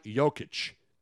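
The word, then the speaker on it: Jokić: Nikolai Jokic. – Or pronounced Jokic. Like Riddlechich. – Riddlechich. – Like Robinchich. You have to end it with a IC. Chich.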